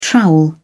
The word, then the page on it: trowel: British English